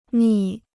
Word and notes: 蜜: mì

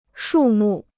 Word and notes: 树木: shù mù

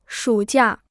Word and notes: 暑假: shǔ jià

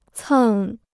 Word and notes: 蹭: cèng

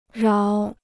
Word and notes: 饶: ráo

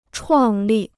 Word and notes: 创立: chuàng lì

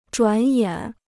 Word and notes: 转眼: zhuǎn yǎn

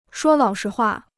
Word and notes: 说老实话: shuō lǎo shi huà

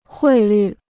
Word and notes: 汇率: huì lǜ

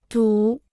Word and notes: 堵: dǔ

